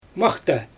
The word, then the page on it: maxta: Xyzyl